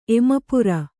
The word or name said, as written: ಎಮಪುರ